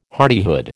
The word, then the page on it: hardihood: American English